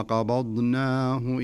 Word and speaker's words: قبضناه: لابد أنك تقلقل عشان يخرج الحرف على طبيعته وعلى سليقته وعلى صحته. ثم قبضناه إلينا قبضا قلقلة في الباء.